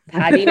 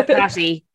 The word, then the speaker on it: Paddy: I saw a really good explanation for why it's Paddy's, and it's because it comes from the Irish word, which is spelled generally it's spelled PADRAIG. So Paddy is the shorter version for pork. Not Patty, which is sort of version for Patrick, which is the anglicized version.